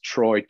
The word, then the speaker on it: Troy: When you actually spell it out, it's Troy, T-R-O-Y, and Alexander spelt like a Russian.